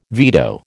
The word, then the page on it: veto: American English